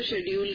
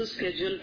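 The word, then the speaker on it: schedule: اصل میں لوگ کچھ اردو میں ہم لوگ اس کو شیڈیول کہتے ہیں لیکن امریکہ کے لوگ اس لفظ کو اسکیڈول پڑھتے ہیں سیم سپیلنگ ہوتے ہیں S C A D U A L کے ساتھ.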